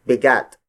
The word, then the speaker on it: bigat: Meanwhile, the Tagalog word for weight is bigat. Bigat. Bigat. Bigat is spelled as B-I-G-A-T.